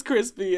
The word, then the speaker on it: Chrispy: And my name is Crispy, and that's spelled C-H-R-I-S-P-Y,